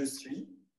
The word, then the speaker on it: suis: So remember the S is silent. Je suis.